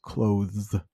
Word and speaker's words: clothes: It's pronounced clothes. Not easy. So again, Americans, or probably the British too, Canadians, we just find easier ways to pronounce something. And I think instead of looking at that TH there, let's just make it an S.